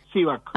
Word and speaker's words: Sivak: No sé si es Sivak, ¿es con acento dónde? – Sivak. – Ah, así. – Sivak, con acento en ley.